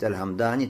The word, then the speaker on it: Аль-Хамдани: Дальше одного и указывает, что Аль-Хамдани, надо вот так произносить Аль-Хамдани, то есть сукуном, буквы мим, хамдани, и даль, мухмаля, мухмаля, то есть без точки, хорошо?